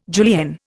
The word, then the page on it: julienne: Indian